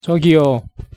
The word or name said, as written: 저기요